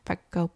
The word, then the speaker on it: Peco: Okay, so it really was only Pekko? Is that how you pronounce his name? – Yeah, Pekko, P-E-C-O, yep.